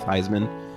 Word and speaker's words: Theismann: Should be docked points for changing the pronunciation of his name to rhyme with Theismann, which is kind of stupid.